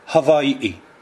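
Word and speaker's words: Hawaii (Hawaiʻi): et que les Hawaïens eux-mêmes prononcent Hawaii.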